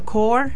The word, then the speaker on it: Corps: Muy buenas, soy del Cuba Court, que se escribe CORPS, es una palabra muy rara en inglés, que significa equipo o grupo de personas trabajando juntos.